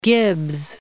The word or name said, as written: Gibbs